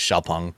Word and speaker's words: Xiaopeng: The proper pronunciation is Xiaopeng.